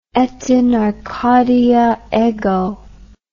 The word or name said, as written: et in Arcadia ego